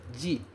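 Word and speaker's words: ji (時): É ji.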